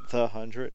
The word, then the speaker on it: The 100: It's called The Hundred, but it's spelled The 100, but it's pronounced The Hundred.